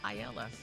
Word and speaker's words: paella: And they were like, "What's paella?" Paella. And I was like, "No, it's paella."